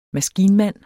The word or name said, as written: maskinmand